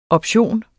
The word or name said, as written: option